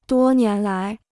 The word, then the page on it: 多年来: duō nián lái